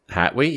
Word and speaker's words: Hatwey: O-T-E-R-O. La Flor de Otero. And it's by Hatwe Cigars. – Yo mama. – How would you say it? H-A-T-W-E-Y? Hatwe? – Hatwe? Yeah, Hatwe. – Hatwe. Yeah, Hatwe Cigars.